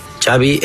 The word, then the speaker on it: Xavi: What is the correct pronunciation of your first and last name? – Xavi Hernandez. – Xavi. Okay. – Yeah. – Sorry. – Xavi like. – Xavi like A, like X-A-V-I.